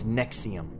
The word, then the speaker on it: NXIVM: The name of this dangerous cult is Nexium, spelled N-X-I-V-M, but pronounced like the medication Nexium.